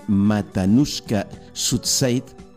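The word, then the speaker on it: Matanuska-Susitna: Nos tenemos que ir al valle de Matanuska-Sutzeit. No sé si lo he dicho bien porque...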